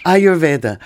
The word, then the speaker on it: Ayurveda: Now, I'm not pronouncing this word, I'm sure. Ayurveda? What is it? – Ayurveda.